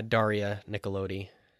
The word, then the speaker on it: Daria Nicolodi: I think one performance you shouldn't overlook here is Daria Nicolodi. Am I saying that correctly? – Yeah, Daria Nicolodi.